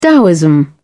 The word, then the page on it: daoism: British English